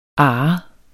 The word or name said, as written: arret